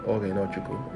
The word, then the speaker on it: Ogheneochuko: But of course, those that know me pre-pharmacist calls me by my first name, and they just call it Ochuko. It's very common, it's easy to pronounce, but I prefer my name to be called Ogheneochuko.